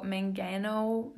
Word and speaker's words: mangano: So mangano. That's how you say it.